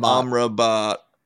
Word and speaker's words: Amrabat: Uh, n- like Casemiro is still good, but we don't have a second midfielder alongside him who's actually producing and a Rambat or Amrabat. Amrabat. Yeah, I was just fixing myself.